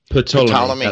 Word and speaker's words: Ptolemy: Ptolemy.